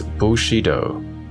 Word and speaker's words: Bushido: The modern Chinese pronunciation is Wu Shi Dao. In Cantonese, Mushido, very similar to tongue pronunciation, whence the Japanese Bushido.